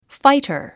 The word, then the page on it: fighter: fǽitə r; ファイター